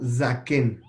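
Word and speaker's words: Zaken (זקן): Zaken.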